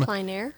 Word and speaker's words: plein air: They have what they call, and I know I don't pronounce it right, it's a French term. – Plein air. – Plein air, something like that.